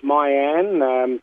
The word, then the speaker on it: Mayan: He's called Mayan. I know it's spelt M-A-Y, but it's Mayan. So I think they were the first civilized people, the Mayans, so they tell me.